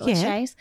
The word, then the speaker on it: Lachey: I really liked Vanessa Lachey's, is that how you pronounce it, Lachey's? – Yeah.